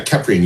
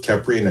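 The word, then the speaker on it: Kheprian: Yeah, Keprian, you've got it correct. And a lot of people pronounce it wrong. And Egyptologists have even said that I might be pronouncing it wrong. So it's all good. We'll go with Keprian, and that's going to be our common pronunciation tonight.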